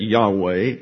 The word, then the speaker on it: Yahweh: There is yet another name which is particularly assigned to God as his special or proper name, and that is the four letters YHWH, we pronounce that Yahweh, or anglicized Jehovah.